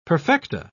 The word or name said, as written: perfecta